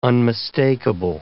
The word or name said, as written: unmistakable